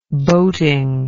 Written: boating

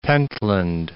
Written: Pentland Firth

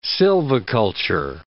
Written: silviculture

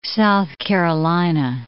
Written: South Carolina

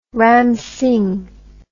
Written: Ram Singh

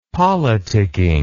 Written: politicking

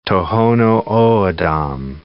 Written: Tohono O'odham

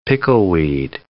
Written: pickleweed

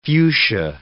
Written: fuchsia

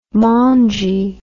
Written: maungy

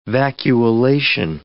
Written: vacuolation

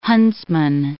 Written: huntsman

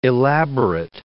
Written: elaborate

